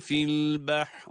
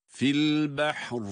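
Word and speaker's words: fil behr (فِي الْبَحْرِ): Evet, burada fil behr diye durdum. Nasıl? Ra'nın kalınlığı ile. Ra'nın aslında esri harekesi var ama Ra'yı esriyle okursak ince okuyacağız. Ama duruş halinde Ra cezimli ise Ra'dan önceki kendisine en yakın olan harekenin etkisinde okunur Ra harfi o zaman. Kendisine en yakın hareke üstün olduğuna göre Ra'yı burada kalın okumak durumundayız.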